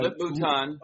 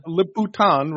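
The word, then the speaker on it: Lip-Bu Tan: Lip-Bu-Tan. – Lip-Bu-Tan, right, who is Chinese, was being dragged through the mud.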